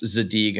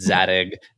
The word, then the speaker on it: Zadig: I also love that you pronounced Zadig and Voltaire, because I've always said Zadig and I feel like such an idiot right now.